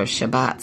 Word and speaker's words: Shabbat: where will we be for Sabbath or Shabbat? Some of them pronounce it that way.